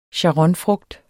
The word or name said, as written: sharonfrugt